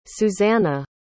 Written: Susanna

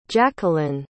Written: jacquelynne

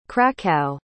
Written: cracow

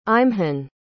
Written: eimhin

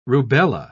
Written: rubella

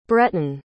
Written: bretton

